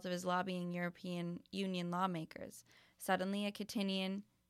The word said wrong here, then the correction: Kittitian: I think this is pronounced Kittitian, yes, Kittitian passport could be presented to Chinese or Russian businessmen as a must-have.